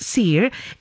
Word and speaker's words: Siir: Well, it is spelled S-I-I-R and pronounced Seer.